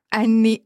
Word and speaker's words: Ann: Kas ma hääldasin seda Anni õieti? – Hääldasid õigesti, et Ann ehk siis aasta aru Anne või siis ingliskeelne annual report, et sealt on see nimi tulnud.